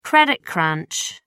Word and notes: credit crunch: American English

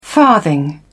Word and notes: farthing: British English

